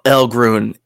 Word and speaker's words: Elghrune: Realms of Elgrun.